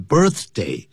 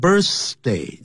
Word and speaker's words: birthday: 谈到这个 birthday, 一定要吐舌头吐出来 birthday。不要念成 birthday, 那就不好听。